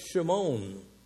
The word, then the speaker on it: Shimon: But Simon was a Hebrew name, and it's pronounced Shimon.